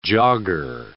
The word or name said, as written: jogger